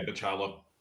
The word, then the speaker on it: Bachalo: Daniel Salvador, Chris Bachalo. I think he's how you pronounce his last name. – Yeah, Bachalo.